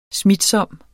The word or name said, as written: smitsom